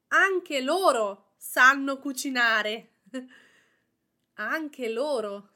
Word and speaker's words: anche: So to recap, the pronunciation of anche is anche, with the emphasis on the first A, and hard sound K, and the final letter closed E, anche.